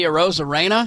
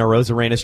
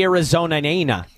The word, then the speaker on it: Arozarena: He cannot say Arozarena. He was calling him. I don't remember how he said it. It was like Randy Arizona-nana. And he kept saying it, and I'm like, dude, I think you're saying this wrong.